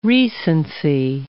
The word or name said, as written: recency